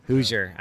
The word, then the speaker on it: Hoosier: Hoosier. – Yeah, I think my folks were Purdue. – Is that how you say it? Hoosier. – Hoosier.